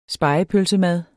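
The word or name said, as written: spegepølsemad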